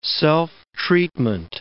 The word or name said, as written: self-treatment